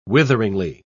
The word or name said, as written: witheringly